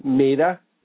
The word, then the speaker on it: Maida: Whose name I inexplicably remember is Tammy Maida, quarterback princess. – Tammy Maida? – Maida, yeah. – How do you spell that? – M-A-I-D-A. – Okay.